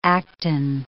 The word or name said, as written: actin